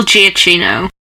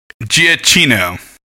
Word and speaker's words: Giacchino: It's Michael Giacchino. – Giacchino. – Yeah. – See, I was going to be like something with an A, C, hard C sound there.